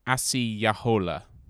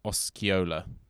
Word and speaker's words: Osceola: So his name is actually mispronounced. So Osceola is how we have anglicized it, but his actual Native American name is Asiyahola, which has now become Osceola somehow.